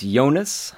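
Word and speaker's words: Yoenis: How do you say Cespedes' first name? – I believe it's Jonas.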